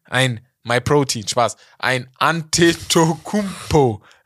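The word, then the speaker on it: Antetokounmpo: Aus dem D bei AD Tokumpo wurde ein NT, ein Ante, Ante, Tokumpo. Weil im Griechischen gibt es kein D. Mm. So. Aus dem O wurde aufgrund der Aussprache, aus dem AD Do Kumbo, wurde ein Ante To ein OU. Ja. Das war aufgrund der Aussprache im Griechischen. Das ist, hören wir jetzt gerade selber gar nicht raus. Bei uns mhm. ist es immer noch ein O, aber bei dem war es dann ein OU.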